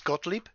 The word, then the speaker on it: Gottlieb: And I think that's just Gottlieb. – Gottlieb, that's it.